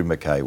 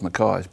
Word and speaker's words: McKay: Well, McKay is the proper Scotch pronunciation from the Gaelic M-A-C-A-O-I-D-H, Mc being son of, and I. So that's why we've always called ourselves McKays, because we were from the northern part of Scotland.